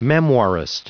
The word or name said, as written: memoirist